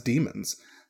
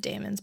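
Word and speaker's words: daemon: Yeah, which even in the show they pronounce that as demons. – So, right. I think that is how it is pronounced, but it's spelled. It looks like it's spelled Damon's. – Yeah, it's the D-A-E. – I always pronounced it Damon's, but what the fuck do I know?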